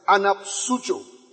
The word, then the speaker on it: anapsucho: A-N-P-S-U-X-I-S. Now, this is a noun. It has a verb, alright, that sounds like this. Anapsucho. A-N-A-P-S-U-C-H-O. A-N-A-P-S-U-C-H-O.